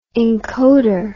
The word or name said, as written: encoder